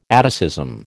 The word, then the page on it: atticism: American English